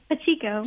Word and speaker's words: Pacheco: Pacheco.